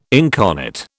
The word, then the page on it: incarnate: British English